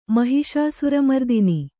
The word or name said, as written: ಮಹಿಷಾಸುರಮರ್ದಿನಿ